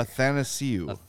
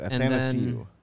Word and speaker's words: Athanasiou: Athanasiou. – It's like. It's like Nathan without the N. – If I think about it, I can say it. Athanasiou. – As I O U.